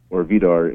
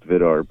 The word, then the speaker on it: Vidarr: Vidarr's Vidarr in correct pronunciation, but since we're just from Pittsburgh and all, it's just Vidarr.